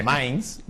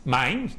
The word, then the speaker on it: Mainz: O Mainz, no sé, allí habrán corregido 30.000 veces cómo se pronuncia. – Mainz. – Mainz, ¿no? Más fácil de lo Pero si lo queremos llamar...